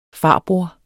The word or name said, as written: farbror